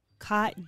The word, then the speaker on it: Côtes: So, I've been corrected on how to say this, but all four rosés are from the Provence region, and it's Côtes. – No S. Côte. – Côte. – Du. – Day. – Du. – Day. – Côte du Provence.